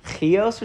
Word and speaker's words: Chios: And sailing from there, we came the following day to, I think it's pronounced like Chios or something crazy when I looked it up in Blue Letter Bible, which I was not expecting.